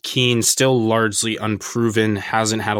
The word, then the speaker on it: Kean: I'm talking about Moise Kean. Apparently, it's pronounced Ken in Italian.